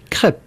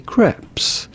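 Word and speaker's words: crêpe: In French, we would say crepe. – Crepe. – Crepe. – Crepe. – The S at the end in French is silent. But in English or American, it'd be fine to say crepes. – Crepes.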